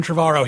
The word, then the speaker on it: Trevorrow: Colin Trevorrow.